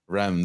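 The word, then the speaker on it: Reims: men's national team in December, and then he went on trial with Rhymes. I hope I'm saying that correctly. – Rem, there you go.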